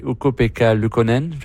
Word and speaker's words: Ukko-Pekka Luukkonen: Newhook, hier, a déjoué Ukko-Pekka Luukkonen.